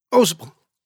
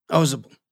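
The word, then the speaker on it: Ausable: Osable. Otto let that point sink in. They pronounced the name differently. Mitchell Osable, three syllables. Otto Osable, one elided syllable, like the locals pronounced it.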